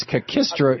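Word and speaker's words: kakistocracy: How do you pronounce it? Is it cacistocracy? – Blue and red skins. – Yeah. Yeah. Is it cacistocracy? Cacistro? – No, it's hard to say. It's cacistocracy. – Cacistocracy.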